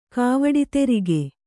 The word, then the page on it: ಕಾವಡಿತೆರಿಗೆ: Kannada